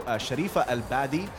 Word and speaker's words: Sharifa Al Badi: We're very delighted to have with us here in the studio Sharifa Al Badi.